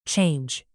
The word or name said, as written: change